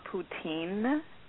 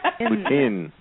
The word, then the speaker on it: poutine: Can I just say it's not poutine? – Uh-oh. Thank you. – What is that? – Pronounced Putin. – Putin. Okay.